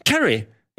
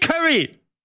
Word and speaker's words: Carey: Carey, ja. Aber wie schreibt man Jim Carey? Mit 2 R und einem Y. Carey, nicht Curry. Carey. So, C-A-R-E-Y.